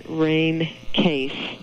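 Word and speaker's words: Rene Caisse: I tend to pronounce her name Rain Case.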